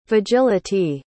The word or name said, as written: vagility